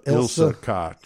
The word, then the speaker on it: Ilsa Koch: Have Ronnie DeFeo and is it Isla Ilsa Koch.